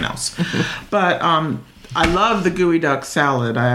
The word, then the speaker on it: geoduck: And for those of you who are not from the Pacific Northwest or just not familiar with gooey duck, it's spelled G-E-O-D-U-C-K.